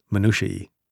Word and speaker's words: minutiae: Yeah, the plural of minutiae, minutiae, which still sounds terrible and wrong to me, but I was instructed to say it, minutiae.